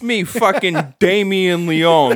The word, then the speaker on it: Leone: Damien Leone was on, which I always called him Leone. It's Leone. The E is silent.